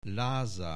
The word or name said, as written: Lázár